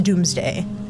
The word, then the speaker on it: Domesday: It's spelled Domesday, Domesday, but it's pronounced Doomsday.